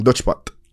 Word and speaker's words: Dutch Pot: Dutch Pot. – Dutchback. – Dutch pot. Like Netherlands. Netherlands. – I'm going to get you to spell it for the viewers. – D-U-T-C-H-P-O-T.